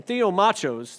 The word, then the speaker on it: theomachos: As a matter of fact, the word here is theomachus. Wait, is that, am I saying that right? Not good with Greek. Yeah, theomachus, machos or something. Theomachos, there you go.